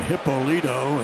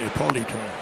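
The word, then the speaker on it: Hipólito: Some people called him Hippolito and some called him Ippolito.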